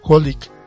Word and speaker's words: Holic: Pero los que más van a tener relevancia también con esta historia son los personajes de la serie de Holic, Triple X Holic, nada más que nuevamente se pronuncia nada más como Holic.